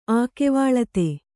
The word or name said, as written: ಆಕೆವಾಳತೆ